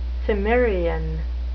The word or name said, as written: Cimmerian